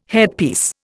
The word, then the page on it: headpiece: Indian